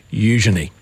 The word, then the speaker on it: Eugenie: Heavy on the U, Eugenie. And then quick on the Je, me.